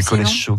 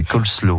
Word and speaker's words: coleslaw: Je disais coleslaw quand j'étais petit, mais coleslaw, voilà, c'est la vraie prononciation.